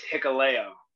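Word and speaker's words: Hikuleo: I hope I'm saying it right. Haikulo. I think I am. I'm not saying it right. – No, even when I first met him, like I didn't know how to, I never said his name, you know, until I had to ask someone, but it's Hikaleo.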